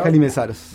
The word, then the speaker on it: Mihaly Meszaros: Mientras tanto, el viernes 3 va a estar Mihaly Mezaros, ¿está bien pronunciado? – Mihaly Mezaros. – Bien.